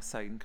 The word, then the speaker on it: Auld Lang Syne: Also, thank you for pronouncing Auld Lang Syne correctly. – Thank you. Did I? Mm-hmm. – You did. If people say Auld Lang Syne, that's incorrect. – So I said it right. Yeah. Thank you very much. – You said Syne, which is correct.